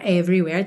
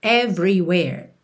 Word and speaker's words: everywhere: Sem mistério aqui, sempre bom lembrar que essas letrinhas E pintadas de cinza e não são pronunciadas, e só na palavra everywhere temos duas. Então, everywhere, everywhere.